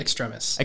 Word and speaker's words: Extremis: So we see what we think is a Black Widow fighting a extremist. That's how you say it, right? – Extremis. – Extremis, gosh.